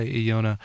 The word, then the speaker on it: Ilona: I believe it's pronounced, since she's French, I'm going to say Iona, I-L-O-N-A.